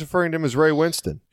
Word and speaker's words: Winstone: There's an E at the end of it, so I always just said Ray Winstone.